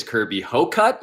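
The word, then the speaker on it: Kirby Hocutt: I appreciate you leaving the pronunciation of the Texas Tech AD's name to me. It is Kirby Hocut.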